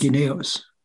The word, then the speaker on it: guineos: It's spelled G-U-I-N-E-O. And it looks like it's pronounced Guineos.